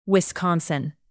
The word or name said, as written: wisconsin